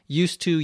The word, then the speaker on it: used to: Now, when you pronounce it, there's no difference. Used to, used to. The D and the T sounds come together as one.